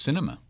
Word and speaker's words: Sinema: Sinema? – I've heard it pronounced cinema. – Cinema, like with movie. A C.